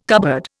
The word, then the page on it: cupboard: Indian